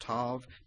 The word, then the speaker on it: Tav: I believe that's how you pronounce it, Tav, T-A-V.